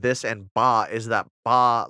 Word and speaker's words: Ba: They're based on Egyptian Ba.